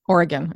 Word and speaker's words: Oregon: So they say Oregon. – Oregon, okay.